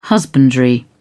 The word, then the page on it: husbandry: British English